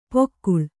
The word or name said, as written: ಪೊಕ್ಕುಳ್